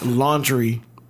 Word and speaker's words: Laundrie: It's spelled LaunDrie, but it's pronounced a Lannister? Is he a Lannister? Do we got any Game of Thrones fans in here? – It's pronounced laundry.